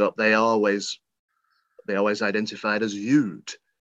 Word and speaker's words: Youd: I've been pronouncing it Yaud, and then I found out recently that's wrong. And it's Sam Yood. So it's spelled Y-O-U-D,